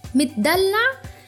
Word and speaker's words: متدلع: متدلع. And focus about with the pronunciation here. It's ميم تاء دال لام عين. متدلع. متدلع.